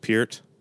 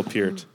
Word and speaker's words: Peart: Also, real quick, it's actually pronounced Peart. – Is it actually? – Ooh. Yeah. – What? – It's one of those things. It's like Reese's Pieces. Reese's Pieces. It's actually pronounced Peart. Neil Peart.